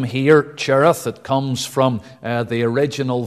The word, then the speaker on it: Cherith: And the Jewish way would be to pronounce it Cherith, the CH being the hard pronunciation.